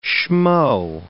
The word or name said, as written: schmo